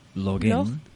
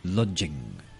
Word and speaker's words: login: The pronunciation, Isabel said lodging, didn't you, Isabel? – Mm-hmm.